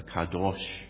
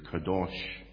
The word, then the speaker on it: Kadosh: And then they added the vowels and it's pronounced, I was always pronouncing it wrong, the Kadosh, according to Derek knows a bit of Hebrew, Kadosh.